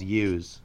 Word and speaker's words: Yews: It is spelt Yews. It's called Yews. Like for like Yeezy, Yews, so it's like Jews.